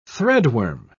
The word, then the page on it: threadworm: British English